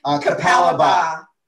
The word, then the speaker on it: Capalaba: It looks like Capalaba. It's like Capalab. It's all these A's, but how is it pronounced? – Capalaba.